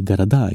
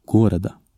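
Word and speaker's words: Garada (города): I plural så hoppar betoningen till slutet av ordet. Man säger Garada, inte Gorada.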